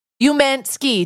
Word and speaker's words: Umansky: Maurizio Umansky.